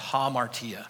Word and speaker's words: hamartia: It looks like hamartia, but it's pronounced hamartia.